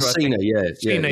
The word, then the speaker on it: John Cena: John Cena, yeah.